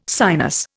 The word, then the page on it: sinus: Indian